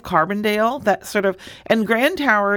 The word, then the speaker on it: Grand Tower: And you know, you said it wrong. It's Grand Tower.